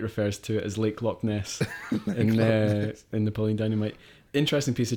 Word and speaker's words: Loch Ness: When JQ says Loch Ness, he really means Loch Ness, which is how the word is actually pronounced.